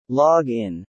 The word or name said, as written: log in